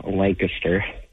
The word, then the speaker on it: Lancaster: It's awesome for- And I know you Californians say it Lancaster, but it's actually pronounced Lancaster.